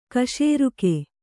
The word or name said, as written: ಖಾಸರೋಗ